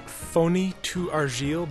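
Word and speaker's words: Foni Tu Argile: I believe it's pronounced phony to argile, but, I'm a print guy.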